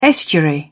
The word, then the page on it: estuary: British English